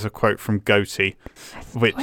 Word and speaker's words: Goethe: Is that how you say his name? I didn't know that's how you said it. – Yeah, it's Goethe.